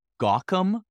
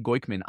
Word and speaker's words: Goykhman: And my last name before, when I was born, G-O-Y-K-H-M-A-N, nobody could spell, nobody could say. It was Gawkham. Most people said Gawkham or Goykham. It was butchered just beyond. So I, instead of Ellie Goykman, I was Eli Gawkham.